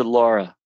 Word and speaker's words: Lara: It's L-A-R-A. – Yeah, but she pronounces it Laura.